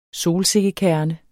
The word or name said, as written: solsikkekerne